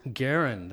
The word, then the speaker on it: Garen: It's a hard G. – Garen? – Garen, that's correct.